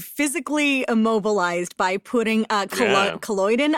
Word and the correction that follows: collodion: I think it's pronounced, in collodion. It's a photographic chemical.